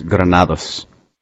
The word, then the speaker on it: Granados: Am I pronouncing that right? Granados. – No, it's Granados.